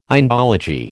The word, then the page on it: Indology: American English